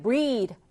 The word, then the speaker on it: breathe: 여기다 e를 붙이면 breathe, 발음이 확 바뀝니다. breath, 얘는 끝에가 th, 혀를 살짝 물어주는 th 사운드예요. 근데 breathe라고 e가 붙게 되면 이 모음과 함께 쓰여서 th가 d가 됩니다. 그래서 breathe가 되는 거예요.